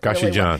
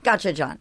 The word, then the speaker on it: Gochujang: You could put a great coleslaw on on it that has a little sriracha or, you know, one of the Korean, how do you say that Korean hot sauce? Guacconchucucuc. I can't say it. – Gochujang. – Really. Gochujang.